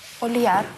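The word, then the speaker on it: olhar: Ольяр.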